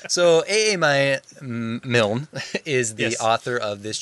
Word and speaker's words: Milne: It's M I L N E. – Here, I wrote it down. – Well, here I got this audio thing. – Milne.